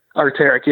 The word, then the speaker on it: Arc'teryx: But there was a couple people that I met that had the Arc'teryx or whatever brand. – Arc'teryx? – I don't know how to say it. Arc'teryx, yes, that one.